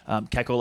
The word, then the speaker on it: Kekko: Kekko like Gecko.